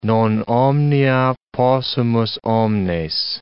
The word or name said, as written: non omnia possumus omnes